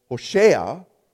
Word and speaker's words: Hoshea: Hosea, or another Jewish pronunciation, was Hoshea, which is a very common name during this time in the Israelites.